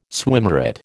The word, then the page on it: swimmeret: American English